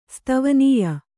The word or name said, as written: ಸ್ತವನೀಯ